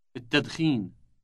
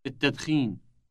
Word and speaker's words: التدخين: التدخين. التدخين. – Note the elongated initial T sound.